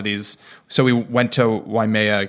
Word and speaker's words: Waimea: Waimea Valley, yes.